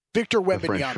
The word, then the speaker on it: Wembanyama: Victor Wembanyama, that's how you pronounce it.